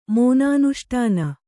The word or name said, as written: ಮೋನಾನುಷ್ಠಾನ